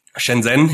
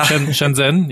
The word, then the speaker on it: Shenzhen: ein Buch gelesen über Shenzhen, wenn ich es richtig ausspreche. Wie spricht man es richtig aus? – Shenzhen, ja. – Shenzhen, ja.